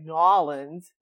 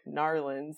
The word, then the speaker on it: New Orleans: But like someone from New Orleans might say like Nolans, where they say the whole word, they just sort of swallow it back as they're saying it, but no one says like Narlins. That's nonsense.